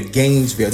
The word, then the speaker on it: Gainesville: We traveled, me and the wife, all the way from Atlanta down here to Gainesville. Did I say that right? – You did.